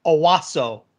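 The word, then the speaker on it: Owusu: Anawosu. – You're putting the N in there. There's no N. It's Owosu.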